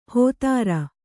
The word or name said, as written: ಹೋತೃ